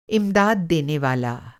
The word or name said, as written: इम्दाद देनेवाला